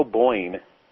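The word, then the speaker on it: O'Beirne: It's spelled O'Byrne, but she's Cato Boyne.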